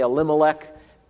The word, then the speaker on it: Elimelech: We always Americanize these pronunciations, right? We say Elimelech. Probably in Hebrew it would be more like Elimelech, okay?